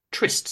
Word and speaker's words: Triste: And then I actually paraphrase another song with the first uh, line Slipping through the shadows of irrelevance, Chester walks down Trist Street. It's spelled triste, Spanish for sad.